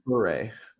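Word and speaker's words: Touré: Do you have any thoughts on this Samore Toure guy? And forgive me if I'm not pronouncing his name right, but he, I know, is like... – If he's French, it's Toure.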